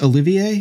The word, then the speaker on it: Olivier: I hate how the French don't pronounce ours. It's Olivier. Is his, is how you pronounce his name? Uh-huh. Like why?